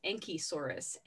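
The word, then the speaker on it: Anchisaurus: The contender for state dinosaur is a dinosaur called Ankhesaurus. Ankhesaurus with A-N-C-H-I is how that is spelled.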